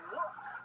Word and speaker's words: lewk: By the way, that's look, but it's spelled L-E-W-K.